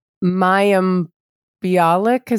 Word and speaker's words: Mayim Bialik: And, Mayim Bialik, is that how you say her name? – Yeah, she's huge on the attachment parenting thing.